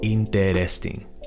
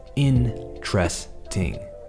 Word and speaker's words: interesting: Now, this word can be tricky to pronounce, and a lot of people read it and think, claro, interesting. Cuatro sílabas. No, that's not really it. What we need to do is ignore that first E pretend it's not there. What you want to say is interesting.